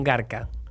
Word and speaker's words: garca: Sos un garca, sos un garca. ¿Lo he dicho bien? – Sí, sí, sí perfecto. Es más, es bastante porteño, así que hasta el acento.